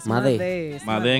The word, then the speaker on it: Madé: No es Made. Made. Pues para mí era Made. es Made porque no veo acento. Made en español. – Es Madé. en español.